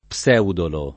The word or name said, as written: Pseudolo